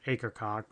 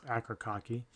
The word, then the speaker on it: Akercocke: I believe that's a correct pronunciation. I used to just call it Akercocke, but it's Akercocke.